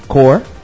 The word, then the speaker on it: Corps: Al final de la Guerra de Siniestro, porque todo esto sucede en el especial de los Sinestro Corps, y por favor no sean ignorantes, no le digan corps, es core. Así se pronuncia.